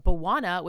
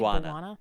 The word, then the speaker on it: Bwana: But Buwana, which is B-W-A-N-A, Buwana, I'm sure that's how you say it, right? – Buwana. Yeah, Buwana.